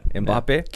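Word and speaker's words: Mbappé: Messi winning, but Messi and Mbappe. Is that how you pronounce his name? – Yeah, Mbappe.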